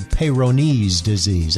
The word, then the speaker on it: Peyronie's: And I think the correct pronunciation is actually Peyronie's disease. I think he was a French surgeon, in case you're interested, way back in the 1700s.